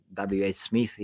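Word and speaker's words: WHSmith: And of course, in the UK, in all the bookstores, the big books like Waterstones and W.H. Smith, if I say it right.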